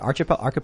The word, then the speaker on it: archipelago: Gary Langley also mentions the Gulag Archipelago. Is it Archipelago? – Archipelago. – I'm always pronouncing that wrong.